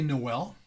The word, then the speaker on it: Noelle: It's spelled different. – I would say Noel. – Noelle or Noelle? – N-O-E-L-L-E. I would say Noel. – Yeah, traditionally that would just be Noel.